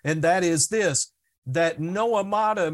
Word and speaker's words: noemata: The word devices is the Greek word noemata. It's a compound word, and let me spell it for you. It's N-O-E-M-A-T-A. N-O-E-M-A-T-A.